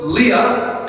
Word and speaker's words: Leah: Leah, how do you say it in English? Leah? – Leah.